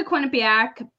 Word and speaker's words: Quinnipiac: Am I saying that right? Quinnipiac. – You are. You said it perfectly.